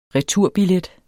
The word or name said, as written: returbillet